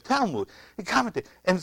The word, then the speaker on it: Talmud: T-A-L-M-U-D. And I was calling it Talmud. And Amy, my daughter, they're not my daughter-in-laws, they're my daughter. She asked how to pronounce that. And it's Talmud, M-O-O-D, I guess.